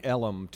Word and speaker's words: Ellum: In Deep Ellum, just east of the skyline in downtown Dallas. – Now explain Ellum to people who are not from Dallas, though. It's how Elm is pronounced.